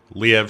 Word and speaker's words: Liev: And I can never pronounce this guy's name right. It's Lee Schreiber, isn't it? – Liev Schreiber, I think.